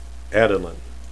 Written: adelin

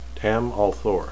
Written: tam

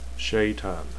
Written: shaitan

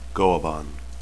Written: goaban

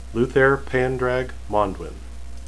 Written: luthair

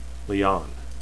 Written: lian

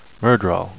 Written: myrddraal

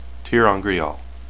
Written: terangreal

